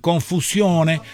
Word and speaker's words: confusione: Y si querés decir confusione, acordate de que la O de Sione, Sione, es fuerte, se pronuncia mucho y la E final tiene mucha presencia.